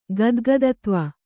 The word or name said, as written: ಗದ್ಗದತ್ವ